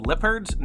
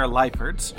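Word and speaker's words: Liepard: You know, I just looked at the pronunciation and it wanted to call them Lippards. No, they're Liperds.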